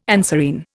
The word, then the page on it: anserine: Indian